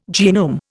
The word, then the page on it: genome: Indian